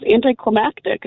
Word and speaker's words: anticlimactic: There's an extra syllable in there. That's where you're going, right? – Yeah. Anticlimactic has to do with climate. So I was like, I had to prove it to my coworkers. I'm like, it's not climatic. It's anticlimactic.